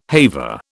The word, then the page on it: haver: British English